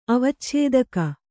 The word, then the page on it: ಅವಚ್ಛೇದಕ: avacchēdaka